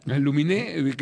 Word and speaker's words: Aluminé: Aluminé, que termina con acento en la E, no, le pone alum...